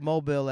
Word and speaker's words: Mobile: The only one that has a crazy mispronunciation that has convinced the country is Mobile. That's not how you say that. That word is mobile. – I was mobile. – Yeah, no, it's Mobile, Alabama, because they have like French roots.